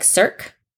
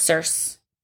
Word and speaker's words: Circe: And so I was like, Cirque? – Yes. – Circe.